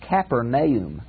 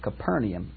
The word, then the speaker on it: Capernaum: Now, Capernaum, I heard a Jewish man pronounce this one. He called it Capernaum. Well, it means the house of Nahum. I've always in English heard it pronounced Capernaum, but Capernaum may be the correct pronunciation.